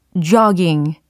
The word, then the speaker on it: jogging: 일단은 이 조깅의 발음을 잘하기 위해서는 턱이 영어로 뭐죠? 쫙 이거잖아요. 쫙. 그래서 그 턱을 쫙, 쫙 늘려주셔야 돼요. j o jogging 이와 같이 조깅은 영어로 jogging, jogging, jogging 하시면 되겠습니다.